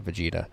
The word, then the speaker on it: Vegeta: We have reviewed your historical records and wish you much luck with defeating Vegeta. I said it right? Yeah, Vegeta.